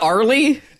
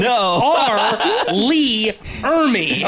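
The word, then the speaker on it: R. Lee Ermey: Oh, it's not Arlie? – No. R, Lee, Ermey.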